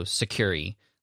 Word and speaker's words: Sucuri: I don't know exactly how to pronounce this, but it's S-U-C-U-R-I. So Sucuri, like security, but Sucuri, S-U-C-U-R-I.